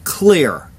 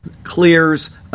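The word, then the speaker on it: Xlear: The DOJ filed a lawsuit in federal court against Utah-based company, I guess it's pronounced Clear, X-L-E-A-R. I don't know how else you'd pronounce that, but it's X-L-E-A-R on October 28th, saying it has deceptively advertised its nasal spray as a treatment and preventative of COVID-19.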